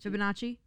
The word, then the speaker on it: Fibonacci: Fibonacci? – Yeah.